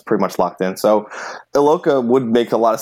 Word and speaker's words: Iloka: George Iakoa? Did I say that right? The Bengals safety just got... – I think it's Iloka. I think it's just regular. It's pronounced like it's spelled.